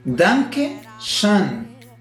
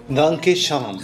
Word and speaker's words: Danke schön: Danke schön. Muchas gracias. – A ver, jueves. – Danke schön.